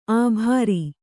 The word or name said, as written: ಆಭಾರಿ